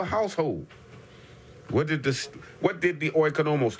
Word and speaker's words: oikonomos: The Greek word is oikonomos. Oikonomos. Spelled O-I-K-O-N-O-M-O-S. Oikonomos. I spell it again. O-I-K-O-N-O-M-O-S.